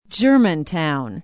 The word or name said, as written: germantown